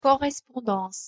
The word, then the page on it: correspondance: French